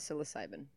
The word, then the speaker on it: psilocybin: It's definitely not psilocybin. I knew it started with a P. – Well, you got it right. – Do you need a beer? – Was it a silent P?